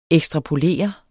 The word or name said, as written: ekstrapolere